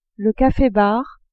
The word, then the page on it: cafe-bar: female